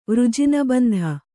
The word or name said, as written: ವೃಜಿನಬಂಧ